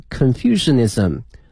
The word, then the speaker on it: Confucianism: 个历史、啊、同时呢我们通过这个石刻能够了解到古代、啊、老百姓的一个日常的生活同时如果你对佛教道教和儒教感兴趣的话呢这些雕刻是必看的这个景点 If you are interested in Buddhism, Taoism and Confucianism 三个教哈，一个是佛教道教和儒教哈。那么第一个呢叫佛教英文的拼写呢是 B-U-D-D-H-I-S-M它的发音是 Buddhism 道教呢是 T-A-O-I-S-M T-A-O-I-S-M 是 Taoism 儒教哈叫 Confucianism 它的拼写是 C-O-N-F-U-C-I-A-N-I-S-M